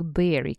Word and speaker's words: bury: Вообще глагольчик berry, который тоже пишется необычно b-u-r-y, b-u-r-y, а произносится как ягода, berry, а это глагол, который значит закапывать.